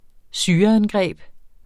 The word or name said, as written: syreangreb